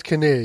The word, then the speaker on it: Kinnear: is it Kinnear? Is that how we pronounce it? It is Greg Kinnear, yeah.